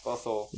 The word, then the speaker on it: bustle: It's a silent T. – Oh. – Bustle. – Bustle.